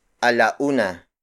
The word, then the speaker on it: alauna: For instance, 1 o'clock in Tagalog is alauna. Alauna. Alauna. Alauna is spelled as A-L-A-U-N-A.